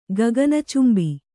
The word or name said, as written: ಗಗನಚುಂಬಿ